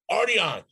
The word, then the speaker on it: Arteon: And I am driving this week, I think maybe one of the prettiest cars that Volkswagen has ever produced, the Arteon, which rhymes with party on.